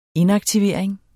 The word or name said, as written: inaktivering